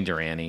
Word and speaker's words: Durante: Yeah, Jimmy Durante, yeah.